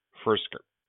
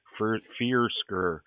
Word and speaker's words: Firkser: And the other guys here are Juno Smith, Mecole Pruitt, and Anthony Firkser. I just butchered that. I am dyslexic, people, and you should see the way that this word looks to me. Firkser.